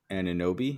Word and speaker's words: Anunoby: OG Anunoby. Did I say that right? – Yeah.